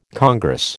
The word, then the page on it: congress: American English